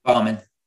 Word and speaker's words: Bauman: They say Bauman, Bowman.